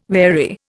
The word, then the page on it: wary: Indian